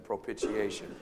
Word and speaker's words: propitiation: It's a fancy five-syllable word. He is the propitiation. There's a propitiation. I know we don't use that word in our modern vocabulary. Everyone say that with me, propitiation.